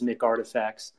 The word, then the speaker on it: McRTFKTs: Yeah, no, I'm a, and just to kick it off, it's a portmanteau of McDonald's and artifacts. So the way to pronounce it is McRTFKTs.